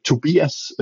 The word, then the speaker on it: Tobias: I think uh, Tobias uh, uh, goes uh, widely internationally, but score is the more yes. tricky part.